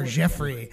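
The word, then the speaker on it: Yefry: And then a guy named Jeffrey Ramirez, which is spelled Y E F, but it's like Zsa Zsa Gabor Jeffrey.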